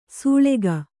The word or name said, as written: ಸೂಳಗಿ